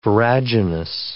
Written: viraginous

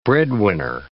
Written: breadwinner

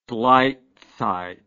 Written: gleitzeit

